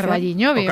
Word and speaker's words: O Carballiño: O Carballiño, no lo sé pronunciar. – O Carballiño.